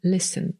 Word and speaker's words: listen: No i nie wymawiamy tutaj litery T, podobnie jak w słowie listen, czyli słuchać.